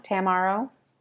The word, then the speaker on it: Tammaro: But it's spelled T-A-M-M-A-R-O. Tom Tamaro. Tamaro.